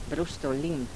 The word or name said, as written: brustulin